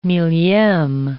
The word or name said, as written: millieme